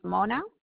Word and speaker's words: Mona: Mine is Mona.